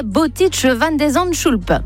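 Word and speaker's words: Botic van de Zandschulp: Le Français affronte ce soir à New York le 70e mondial, le Néerlandais Boutic van de Zandschulp.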